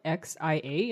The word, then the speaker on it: Xia: So the kingdom is it's spelled XIA in the romanization, and it's pronounced Xia.